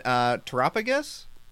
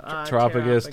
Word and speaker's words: Terapagos: And then Terapagos? Is that how you say it? – I think so. – Terapagos, Terapagos. – Yeah, I feel like it'd be, like, maybe, like, Galapagos, which, like that.